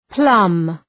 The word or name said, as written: plumb